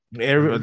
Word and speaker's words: Erivo: Is it Erivo? – Erivo. That's how I pronounce it.